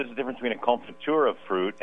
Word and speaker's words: confiture: Yeah, it's a French word, confiture. It's spelled C-O-N-F-I-T-U-R-E, I believe.